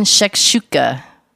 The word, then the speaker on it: shakshuka: We are doing very green shakshuka.